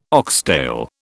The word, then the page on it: oxtail: British English